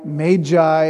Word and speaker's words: Magi: I think it's Magi. That's the original pronunciation of the word. The Greek word for it is magos, the plural magoi.